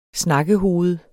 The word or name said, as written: snakkehoved